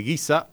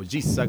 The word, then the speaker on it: Giza: una puesta en escena de luces tremenda en la pirámide de Giza, o Giza, como lo quieran pronunciar,